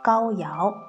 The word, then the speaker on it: 皋陶: 尧呢，其实就是我们平时说的陶瓷的陶，这个字在这里是读成尧的。这里你们可以注意一下，记一下，高尧是个人的名字啊。